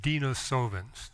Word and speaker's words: Denisovans: The correct pronunciation is Denisovans.